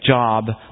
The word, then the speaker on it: Job: I know it looks like Job, but in English, for some reason, we pronounce it Job.